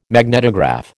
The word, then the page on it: magnetograph: American English